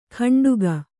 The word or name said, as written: ಕಂಡುಗ